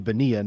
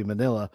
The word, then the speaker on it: Bonilla: And here's the part of the podcast where I get to correct your pronunciation and say that it's Bobby Bonilla, not Bobby Manila, which I'm happy to do.